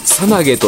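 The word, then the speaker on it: 猿投: 猿を投げると書いて、えー、さなげと読む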